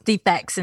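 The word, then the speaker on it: DFACS: It's that, pronounced defects in Georgia.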